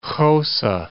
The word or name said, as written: Xhosa